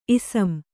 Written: ಇಸಂ

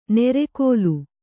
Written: ನೆರೆಕೋಲು

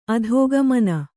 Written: ಅಧೋಗಮನೆ

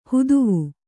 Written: ಹುದುವು

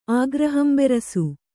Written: ಆಗ್ರಹಂಬೆರಸು

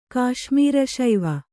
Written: ಕಾಶ್ಮೀರಶೈವ

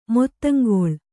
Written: ಮೊತ್ತಂಗೊಳ್

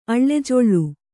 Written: ಆಲಾಜೋಲಿ

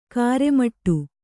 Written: ಕರ್ಮಠ

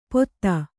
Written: ಪೊತ್ತ

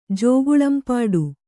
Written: ಜೋಗುಳಂಪಾಡು

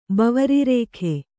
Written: ಬವರಿರೇಖೆ

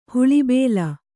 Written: ಹುಲಿಬಲೆ

